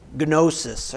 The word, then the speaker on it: gnosis: Well, it comes actually from a Greek word, gnosis. Or that's how it's spelled, G-N-O-S-I-S.